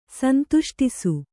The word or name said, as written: ಸಂತುಷ್ಟಿಸು